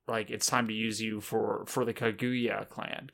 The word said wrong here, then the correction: Kaguya: Kaguya? Sure. – Kaguya. – Kaguya. I was putting the emphasis in the wrong syllable, as I typically do.